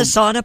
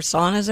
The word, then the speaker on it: sauna: Is that the sauna place where there are saunas everywhere? – It's sure. Pronounced sauna. – Oh, yeah. Sorry.